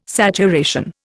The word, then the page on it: saturation: Indian